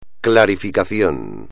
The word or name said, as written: clarificación